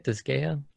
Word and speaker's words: Disgaea: And I'm pretty interested, like, after seeing the little cartoony animations of the Disgaea, the Disgaea 6. – Mm-hmm. – Is that how you say it? Disgaea? – Disgaea.